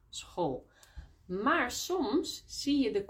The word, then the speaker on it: school: Maar soms hebben we CH, zo CH, combinatie, die we ook uitspreken als een G. Bijvoorbeeld, for example, in school. S-C-H, school. Of schattig, cute. S-C-H, schattig.